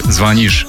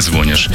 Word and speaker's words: звонишь: Но на всякий случай напомню, что правильно говорить «ты звонишь ей домой, а не звонишь».